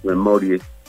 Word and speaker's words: memoriae: Uno es eh, el lapsus memorie, así se llama, lapsus memorie, se escribe memoriae en latín